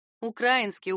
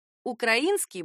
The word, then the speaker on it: украинский: Но орфоэпические словари предупреждают: «украинский», «украинцы», «украинки» — неправильные варианты. Правильно только так: «украинский борщ», «гостеприимные украинцы», «хлебосольные украинки».